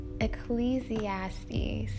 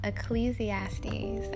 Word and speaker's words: Ecclesiastes: Okay, the way I just butchered that pronunciation, it's Ecclesiastes. Ecclesiastes.